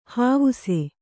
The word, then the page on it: ಹಾವುಸೆ: hāvuse